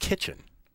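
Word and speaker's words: Kichen: And it's just kitchen. Like the room in your house.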